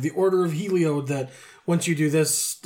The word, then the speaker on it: Heliod: No, Helioid. – Heliod, sure. – Helioid sounds like some sort of cystic growth that you might have on your undercarriage. – Anyways, so... Heliod. Heliod. – Spell it. – H-E-L-I-O-D.